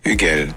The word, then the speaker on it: Hugel: Abbiamo fatto anche, ogni volta che pronunci Hugel c'è da fare il check sulla pronuncia, e si pronuncia così, senti. – This DJ is called DJ Hugel.